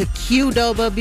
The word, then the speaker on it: Qdoba: It's the 8 o'clock mix block, and while you might be on your way driving to Qdoba, because trust, I did check, that is how you say it.